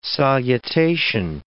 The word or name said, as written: salutation